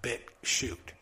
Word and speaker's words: BitChute: Some people think BitChute is spelled like S-H-O-O-T, but it's not. It's spelled bit and then shoot like a coal shoot, like BitChute, like C-H-U-T-E.